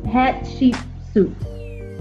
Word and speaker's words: Hatshepsut: H-A-T-S-H-E. It got she in the name. H-A-T-S-H-E-P-S-U-T. Let me see if Google can pronounce it for me. Hatshepsut.